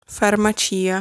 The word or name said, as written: Farmacia